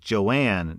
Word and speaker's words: Joan: It was owned by a husband and wife, and the wife's name was spelled J-O-A-N, but it was pronounced like Joanne, not Joan.